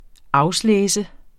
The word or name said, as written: auslese